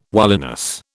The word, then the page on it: wiliness: British English